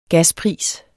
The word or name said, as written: gaspris